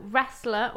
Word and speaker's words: wrestle: And the T as well is silent. – Is it? – Yeah, wrestle. Unless you're saying wrestle. No, yeah, wrestler. Wrestling. Wrestle. – So, silent of W and T.